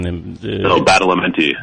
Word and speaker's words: Badalamenti: Badalamenti.